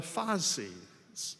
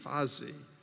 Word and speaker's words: fasces: And so Paul and Silas were actually beaten with the rod part of this bundle of sticks called a fasces. Fasces. Spelled F-A-C, but it's pronounced F-A-S-E-E-Z, Fazi.